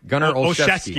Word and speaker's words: Gunner Olszewski: How do you say the name again? – Gunner Olszewski. – Olszewski. Okay.